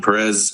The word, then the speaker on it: Perez: My name is Tahin Perez. That's spelled T-A-apostrophe-J-I-N, last name P-E-R-E-Z, with Western Native Voice.